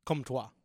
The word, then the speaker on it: Comtois: Comtois? Am I saying that right? – Comtois. – I should have known that. Kokkaniemi centering that group with Nason.